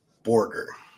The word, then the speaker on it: Borger: Borger.